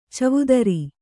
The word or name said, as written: ಚವುದರಿ